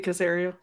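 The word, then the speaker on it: Caserio: Or Sirianni, good gracious. Cesario. Good gracious, I'm butchering his name. But anyway, the GM of the team. – Nick Cesario, yep. – Yeah. Yeah, I said his name right the second time.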